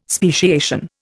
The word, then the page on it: speciation: Indian